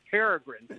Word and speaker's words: peregrine: And she pronounced it like that. So we're little kids look up there saying, how can she see two green birds all the way up there? And we did not laugh because she'd have walked this one. But because she mispronounced it. It was years before I realized it was peregrine.